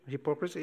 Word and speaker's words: hypocrisy: Oh, is the right pronunciation? Hypocrisy? Yeah, thank you.